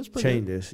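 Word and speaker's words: Schoendienst: Chendis, I don't know how to say his name. – That was That's pretty close. – That's it. – Yeah, but Chendis. Yeah, Chendis, yeah, and he was awesome.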